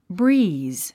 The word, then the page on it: breeze: bríːz; ブリーズ